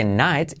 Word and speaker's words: knight: Caballero, eh, que se pronuncia como noche, night, y de hecho la K tampoco se pronuncia, ¿vale? No se dice night, sino night, night.